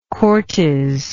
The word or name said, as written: cortes